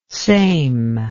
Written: sejm

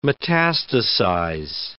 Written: metastasize

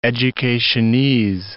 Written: educationese

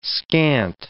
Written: scant

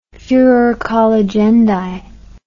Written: furor colligendi

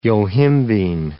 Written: yohimbine

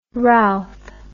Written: rowth

